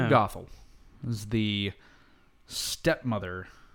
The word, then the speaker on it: Gothel: Gothel. That's it. Mother Gothel. Mother Gothel. G-O-T-H-E-L.